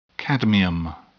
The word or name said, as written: cadmium